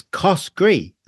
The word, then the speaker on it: Coskrie: For listeners, it's Coskrie. So yeah, C-O-S-K-R-I-E. And my whole life, I always thought it was Coskrie.